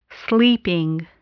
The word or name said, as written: sleeping